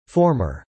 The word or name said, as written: former